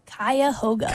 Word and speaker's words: Cuyahoga: And this song here, I believe I'm pronouncing this right, Across the Cuyahoga? – It's Cuyahoga.